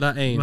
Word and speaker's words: La Haine: Like the H is silent. – La Haine.